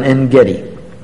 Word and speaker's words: En-Gedi: On the first occasion, David and his men were hiding out in En-Gedi. That's the anglicized pronunciation.